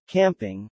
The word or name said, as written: camping